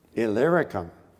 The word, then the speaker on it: Illyricum: Illyricum.